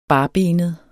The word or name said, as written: barbenet